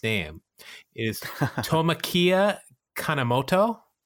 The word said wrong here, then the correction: Tomoaki Kanemoto: Tomoaki Kanemoto. Yeah.